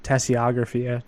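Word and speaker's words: tasseography: And reading tea leaves, or tassiography is probably the right way to pronounce it, is a type of, you guessed it, divination, since she's the one teaching divinations.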